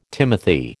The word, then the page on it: timothy: American English